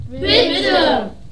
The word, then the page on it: bitte: german